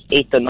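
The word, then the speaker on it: Athan: My name's Ethan, not Ethan.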